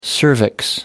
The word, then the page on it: cervix: American English